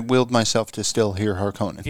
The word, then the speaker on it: Harkonnen: And the Harkonnens, that's hard for me to say it like that. Harkonnens. – Oh, it's a Harkonnen all day long.